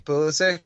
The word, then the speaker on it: Pulisic: Is it Pulisic? – Pulisic.